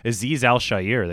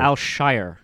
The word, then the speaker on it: Azeez Al-Shaair: I think he pronounces it Azeez Al-Shaair. – Azeez Al-Shaair. – Al-Shaair. It's Al-Shaair.